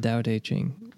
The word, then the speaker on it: Tao Te Ching: The Tao Te Ching.